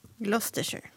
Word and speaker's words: Gloucestershire: Ja, alltså, jag tror att Adrian har rätt där, att det är Four Men from Gloucestershire eller Leicestershire. – Ja, men säg det. – Men jag vet inte. Visst, Gloucestershire. – Gloucestershire.